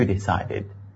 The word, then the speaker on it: decide: 那么这一课的话，我们要讲句型之前的话，一定要请大家注意一个单词的读音，就是决定这个单词 D-E-C-I-D-E decide, 是吧？那么大家一定注意的就是说这个 I 的音呢要发的饱满圆润哈，而且这个单词的它的重音在 C 这个地方，所以应该读成这样的哈。